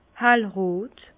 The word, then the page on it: hellrot: Bas Rhin; Herrlisheim